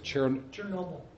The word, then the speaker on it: Chernobyl: Look at Chernobyl, or how do you pronounce it? – Chernobyl.